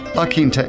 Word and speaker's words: La Quinta: So, for all of you nice folks out there who want to pass the good word on, the good word is pronounced La Quinta. Although, now that we think about it, if you pronounce it right, probably no one will know who you're talking about. La Quinta Inn, where you can find true happiness in hotel accommodations.